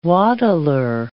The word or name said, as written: waddler